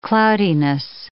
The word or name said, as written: cloudiness